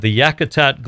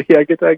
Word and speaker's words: Yakutat: The one that I find is stunning is the loss of ice from, I'll probably pronounce it wrong, the Yakutat Glacier in Alaska. – Oh, you got it just right. – Oh, nice. – The Yakutat Glacier.